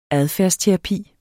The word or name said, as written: adfærdsterapi